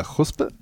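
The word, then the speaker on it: Chuzpe: Ja, du, du, hast mir dieses Wort beigebracht, äh, Chuspe. Ist das richtig ausgesprochen? Ja, ganz genau.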